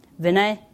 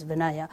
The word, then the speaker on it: Vinaya: Vinay is the term from where the term Vinaya comes. In English, you pronounce it as Vinaya, but the traditional Sanskrit term is Vinay.